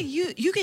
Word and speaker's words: 遊戯: なんか遊戯王の遊戯だと思ったら、これで湯気って読むんですね。